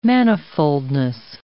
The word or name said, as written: manifoldness